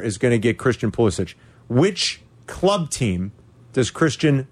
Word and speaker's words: Pulisic: So we're going to give away Pulisic. Am I saying it right, Yurko? They say Pulisic. – You are to me, Pulisic.